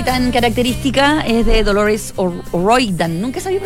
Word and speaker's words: O'Riordan: O'Riordan. – Tal cual. Claro, porque por su origen eh, escocés se tiene que pronunciar tal cual. O'Riordan.